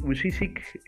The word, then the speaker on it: Vujicic: Después está el caso, por ejemplo, que a mí me gustó mucho, que recomiendo que lean la vida de Nick Bujosic. Creo que se pronuncia así, Nick Bu perdón. Eh, Nick Vujicic eh, fue un, muchacho, un joven que nació con, con, una discapacidad que en este momento no recuerdo qué era.